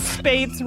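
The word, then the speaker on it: Spaihts: Okay, so it was written by John Spatas. – It literally says pronounce Spates right after that.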